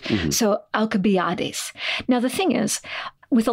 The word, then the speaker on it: Alcibiades: So in Greek, Alcibiades, you might have pronounced it something more like Alcibiades, because the letter that we see as C, the letter C as in cookie or, I don't know, circle or something, in English, we pronounce it as a soft C, Alcibiades.